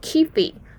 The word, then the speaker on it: Kyphi: 好，那在埃及的祭司呢，他们留下来的众多配方呢，呃，其实有一个配方是有保存下来的，这个名字呢，拉丁文叫做 Kifi。英文发音好像念 Kyphi 吧，它的拼法呢是 K Y P H I。